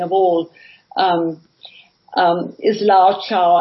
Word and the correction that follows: Lao Tzu: and I can never pronounce it, but he's spelled L-A-O-T-Z-U, as you know, and that is always Lao Tzu, yeah.